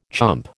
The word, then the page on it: chump: American English